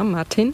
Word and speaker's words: Martin: Martin, spricht man das? – Ja, Martin.